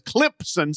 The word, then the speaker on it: Clemson: And whether it's semi-tough or whether it's in You Gotta Play Hurt, he refers to it as Clemson, C-L-I-M-P-S-O-N. In there, he has southern pronunciations of several schools. He's got Florida State, is basically Flar State, Clemson.